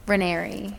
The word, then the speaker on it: Raineri: Raineri. It's like canary, but with an R.